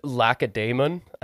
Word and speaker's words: Lacedaemon: Lacedaemon, I think is how you pronounce it. – You betcha.